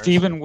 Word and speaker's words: Steven: It's Stephen Willison, S-T-V-E-N. Last name's Willison, W-I-L-L-I-S-O-N.